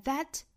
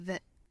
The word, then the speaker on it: that: 后面 and that, 这里面的 that 并不是一个虚词，所以它不能够弱读成 the, 应该是 that.